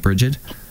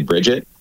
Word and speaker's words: Brigid: Well, that's kind of a tough question to answer, because there's sort of a standard way of pronouncing the name in English, and it's totally different than how it's pronounced in Irish or in Scots Gaelic. So probably most people in English say Brigid, uh-huh. and then in Irish it's usually some version of Brigid or Brigid.